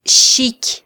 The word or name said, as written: xiky